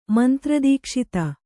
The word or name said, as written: ಮಂತ್ರದೀಕ್ಷಿತ